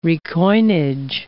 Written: recoinage

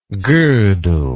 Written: godel

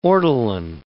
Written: ortolan

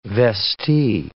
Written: vestee

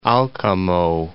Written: Alcamo

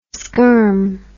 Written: skerm

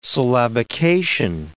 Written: syllabication

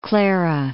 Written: Santa Clara

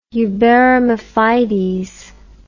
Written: uberrima fides

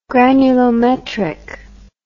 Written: granulometric

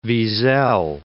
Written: Wiesel

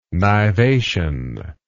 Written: nivation

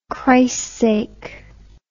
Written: chrissake

